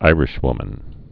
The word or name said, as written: Irishwoman